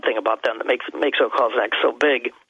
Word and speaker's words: Özkonak: One of the most amazing cities they found, the biggest city they found, is a place called Özkonak. I can spell that for you. O-Z-K-O-N-A-K.